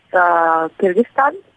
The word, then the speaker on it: Kyrgyzstan: How do you pronounce that town? – The country or the city? – Oh, both. – Okay, it's country's Kyrgyzstan, and the city is the capital, Bishkek.